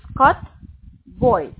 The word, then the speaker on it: Scott Boyd: It's Scott Boyd.